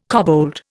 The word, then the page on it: kobold: Indian